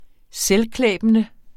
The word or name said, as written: selvklæbende